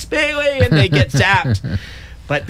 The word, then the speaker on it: Pele: So is it Pele? – Pele. – Pele. – Yeah.